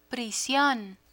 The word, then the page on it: prision: Spanish